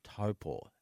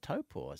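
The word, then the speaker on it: Taupō: Yes, it's spelt Taupo, but the Māori pronounce it as Taupo. And that's, Taupo.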